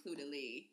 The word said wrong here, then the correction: Caudalie: How are you pronouncing it? – I say Caudalie. – Or maybe it's not even an L in there. Whatever. – No, it's an L. It's Caudalie.